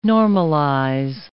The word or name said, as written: normalize